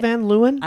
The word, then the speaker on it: Van Leeuwen: I love the Van Loon. Is that how you say it? Van Leeuwen? – I like Van Leeuwen.